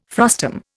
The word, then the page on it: frustum: Indian